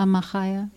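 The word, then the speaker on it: mechaya: Did I say that right? A machaya? – Yes.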